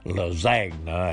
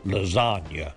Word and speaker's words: lasagna: I was saying lasagna, and that ain't right. He was, he told me it was lasagna,